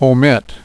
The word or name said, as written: omit